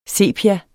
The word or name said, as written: sepia